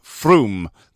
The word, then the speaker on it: Frome: It's spelt Frome, but I'm pretty sure it's pronounced Frome.